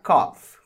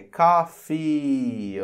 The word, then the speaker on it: coffee: Eu não vou pronunciar cough, como muita gente pronuncia. É coffee, ok?